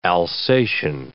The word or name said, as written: Alsatian